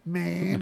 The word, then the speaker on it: Maynard: Maynard. That's how you have to say his name.